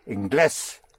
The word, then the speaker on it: Inglés: I-N-G-L-E-S. – I-N-G-L-E-S. According to the nose rule, I would pronounce it Inglés. – Then there's an accent. – Where? – Over the E. – Right. So I-N-G-L-E, accent over the E-S, Inglés. Because without the accent, it would be Inglés.